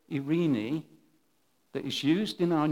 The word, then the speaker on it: eirene: And I had a chat with David earlier, and this word is spelled E-I-R-E-N-E. And I think if you said Irene, nearly right, it'll do.